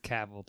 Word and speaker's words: Cavill: It's Cavill, too, by the way. He doesn't say Cavill. – Cavill. – He says Cavill.